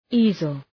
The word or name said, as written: easel